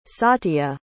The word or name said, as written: satya